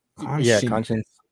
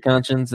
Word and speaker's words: Conscience: How do you say that? Conscience. – Conscience. – Like conscious. Yeah, I figured Conscience and Jay Black.